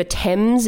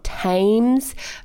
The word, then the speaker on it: Thames: The River Thames is also one. It's not Thames.